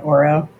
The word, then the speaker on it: OARO: Sure, and I think most of us in North America just call it OARO, just the way phonetically you would pronounce it.